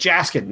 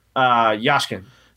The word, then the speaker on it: Yashkin: I was like Jaskin. No, that's not right. Yashkin.